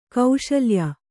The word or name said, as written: ಕೌಸಲೇಯ